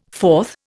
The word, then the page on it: forth: Indian